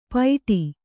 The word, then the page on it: ಪೈಟಿ: paiṭi